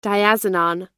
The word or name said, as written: diazinon